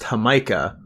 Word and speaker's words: Jamaica: it looks like Jamaica, but I'm pretty sure it's pronounced Jamaica. Because it's like the... I think it's basically hibiscus.